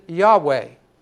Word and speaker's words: Yahweh: The Lord's proper name is a combination of four letters, four capital letters, YAWH. We pronounce it Yahweh.